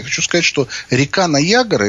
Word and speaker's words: Ниагара: У нас он называется Ниагара, потому что так он пишется, а на самом деле они все зовут Ниагара.